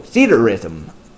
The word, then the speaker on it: Theatrhythm: That's spelled, let's see, THEATRHYTHM, all one word. I would have pronounced it Theatrhythm, but Theater Rhythm, I guess, makes a lot more sense with the meaning of the name. So, Theater Rhythm.